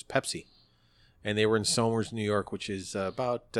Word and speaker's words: Somers: It looks like Somers, but it's pronounced Somers.